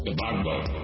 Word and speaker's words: Gbagbo: His name is spelled G-B-A-G-B-O. Gbagbo.